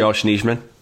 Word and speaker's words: Nijman: Nijma, how do you say his last name? – Yosh Nijman.